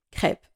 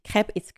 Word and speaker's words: crêpe: If I tell you I want to eat crepe. It's not pancakes because it's different. Crepe, it's written C-R-E with a circumflex accent. That's how you say it, but that's how you written it in French. P-E-S.